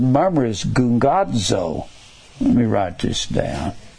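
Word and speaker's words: gungadzo (γογγύζω): Two G's together is pronounced NG gungadzo.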